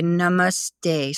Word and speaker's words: namaste: So this one is, everybody will say namaste, something like that. But you have to accent on the second A and the last A because of accentuation in Sanskrit and metrics.